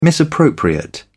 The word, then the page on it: misappropriate: British English